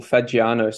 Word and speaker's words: Fagiano: So it's actually pretty simple, Fagiano. So it's an Italian bird.